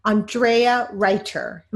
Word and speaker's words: Andrea Reiter: Today, I have a very special guest, Andrea Reiter.